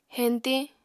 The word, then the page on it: heñtiñ: Apatani